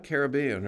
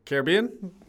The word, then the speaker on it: Caribbean: That's what's frequently said, but it's actually Pirates of the Caribbean because the Caribbean pronunciation is the noun and the name of the sea, and Caribbean is the adjective, so you could take a Caribbean cruise to the Caribbean.